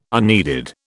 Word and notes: unneeded: British English

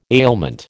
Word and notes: ailment: American English

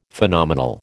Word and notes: Phenomenal: American English

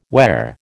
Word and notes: where: American English

